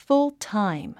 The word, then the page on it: full-time: fúltáim; フルタァイム